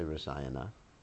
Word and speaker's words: rasayana: And I say rasayana. Kata. Would you say kat? Yeah, you say kat. Kata. Rasayana. Oh, because there's a long A there. Yeah, you only get rid of the short A's.